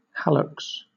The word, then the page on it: hallux: British English